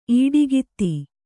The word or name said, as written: ಇಡುಕೈದು